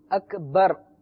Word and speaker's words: اکبر: اب ایک خاص ایک مسئلے کی طرف توجہ دلانا چاہتا ہوں کہ اللہ اکبر, یہ اکبر با کے بعد راہ با را زبر بر. – ٹھیک ہے. – اکبر کا مطلب ہوتا ہے سب سے بڑا, بڑی بڑائی والا, بہت کبریائی والا.